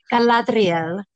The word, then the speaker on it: Galadriel: el Adar, cuando, Adar, Adar, Pues cuando a él lo capturan, que Galadriel, como que no hay otra forma de decir ese nombre, si no lo dice así, lo estás pronunciando mal. Este, sí, Galadriel,